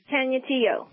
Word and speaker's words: Canutillo: All right, let's talk who's in Canutillo, Texas. Is that how you pronounce it? – Yes. – It's Canutillo, right? – Canutillo.